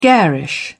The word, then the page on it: garish: British English